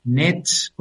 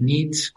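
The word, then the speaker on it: NEET: los nets con dos es o los nits con dos es si pronuncias la e como i o como quieras.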